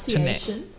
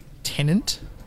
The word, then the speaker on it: Tenet: So like, this is the movie titles, like Tenet, T-E-N-E-T, but like Tenet. – Tenet. – But like the two or three times it's in the movie, it's pronounced like tenant, like a tenant of a house, like T-E-N-A-N-T.